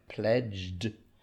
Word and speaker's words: pledged: Yeah, we've got the D sound at the end. Pledged